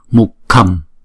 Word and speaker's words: mukham: Chữ K rồi đọc cái hơi nó mạnh ra một chút, K, K. Thì nó giống như chữ khờ của Việt Nam, nhưng mà không phải là khờ, mà là khờ, khờ, khờ. Đó. Cho nên chữ này chúng ta đọc là mục khầm, mục khầm, mục khầm.